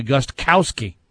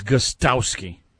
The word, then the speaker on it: Gostkowski: It's Gostkowski. There is no K. It's silent.